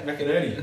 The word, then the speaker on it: McInerney: McInerney. – Yeah, that's pretty good. – Yeah, McInerney.